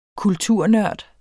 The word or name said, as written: kulturnørd